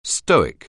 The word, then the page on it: stoic: anglais